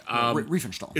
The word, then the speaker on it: Riefenstahl: In particular, Leni Riefenstahl, I think I'm pronouncing that right, I could be wrong. – Riefenstahl. – Is it Riefenstahl? – Yeah.